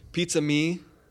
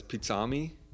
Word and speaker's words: Pizzami: Pizza Me, people. Uh, mistakenly pronounce it as pizzami.